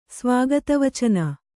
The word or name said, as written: ಸ್ವಾಗತವಚನ